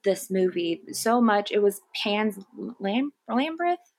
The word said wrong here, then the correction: Labyrinth: Am I pronouncing that right? – Pan's Lab- Labyrinth.